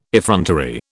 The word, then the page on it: effrontery: British English